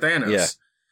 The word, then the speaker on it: Thanos: And I'd say Thanos. That's how I've always pronounced it, Thanos. – Yeah. – But a different pronunciation of it. – Thanos. – I have always said it Thanos since the 90s.